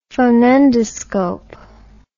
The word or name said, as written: phonendoscope